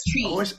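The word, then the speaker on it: Twi: And she taught me, he speaks Chui. Am I saying it correctly? – Yeah, yeah. Yes, Chui.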